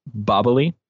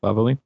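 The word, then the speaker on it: Babili: Bobbly, is how it's pronounced. Yeah, Bobbly. It's the Billy. – Bobbly, Bobbly. If you type its name into the say command, it will pronounce it correctly.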